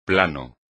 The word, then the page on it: plano: espagnol